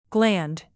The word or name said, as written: gland